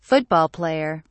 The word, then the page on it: football player: British English